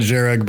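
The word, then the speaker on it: Jhereg: How do you pronounce the name of the book? – Zhereg. – Okay. Because a J next to an H does not make sense to me.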